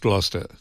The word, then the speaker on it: Gloucester: Yeah, it's pronounced Gloucester.